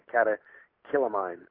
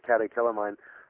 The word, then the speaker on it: catecholamine: And the reason why is because stress or anxiety causes the body to produce a chemical called a catecholaminus or a catecholamine. And it's hard to pronounce that one, but catecholamine.